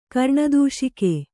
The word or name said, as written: ಕರ್ಣದೂಷಿಕೆ